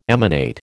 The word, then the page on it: emanate: American English